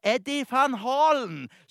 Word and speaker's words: Eddie Van Halen: Det var han Eddie Van Halen. – Ja, ikke helt 100% riktig. Jeg tror faktisk at det uttales Eddie Van Halen.